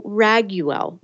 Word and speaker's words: Raguel: We are going to explore how Archangel Raguel, now that's how I pronounce it. It's R-A-G-U-E-L.